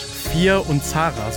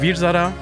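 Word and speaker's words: Veer-Zaara: Und zwar zu Deutsch Fir und Zara. So haben die das wirklich immer ausgesprochen. Im Indischen oder im, so wie ich es gelernt habe, heißt der Virzada.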